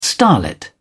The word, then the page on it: starlet: British English